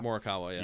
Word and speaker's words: Morikawa: Morikawa. – Morikawa, yeah.